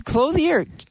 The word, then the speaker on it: clothier: It's a national discount clothier. Clothier. I don't know how to pronounce it. – Clothing store? – Well, it's clothier. C-L-O-T-H-I-E-R.